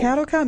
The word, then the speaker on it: Caldecott: The Caldecott Medal is awarded to the artist of the most distinguished American picture book for children from the previous year, since 1937.